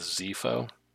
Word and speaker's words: Xifo: I'm going to go with Xifo. It's X-I-F-O is his last name. – Yeah, I think that's right.